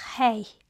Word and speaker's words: He: It's called He, and it's spelled H-E-H. It represents the breath. Even when you say it, you cannot say it without releasing breath.